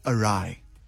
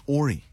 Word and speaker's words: awry: So when I was reading it in books, I always read in my mind, it came out as Ori, because that's how it's spelled, like A-W-R-Y. And like the whole time my mind is saying Ori, Ori, Ori, Ori.